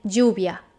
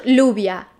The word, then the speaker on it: lluvia: Now, when you have two L's together, it's pronounced J, as in lluvia. Not lluvia, remember? Talla, not tala. Toalla, not toala. Paella, not paella, okay?